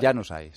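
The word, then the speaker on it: Januzaj: Janusaj. – Janusaj.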